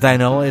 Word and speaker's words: Dinola: We started things out with the lovely and talented Dinola. At least I think it's Dinola. It's spelled like Shinola. D-I-N-O-L-A.